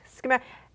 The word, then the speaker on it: Scamander: Scamander? Is that how you say it? – Newt Scamander. Yeah, I mean, it's weird.